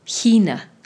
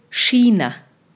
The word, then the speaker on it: China: Lo más común es decir China. Repite, por favor, China. Esta es una CH como en la palabra ich. Algunas personas, especialmente alrededor de Berlín, suelen decir China, con SCH, digamos. China. Muy bien, y algunos pocos los van a escuchar que digan Kina, con K, Kina.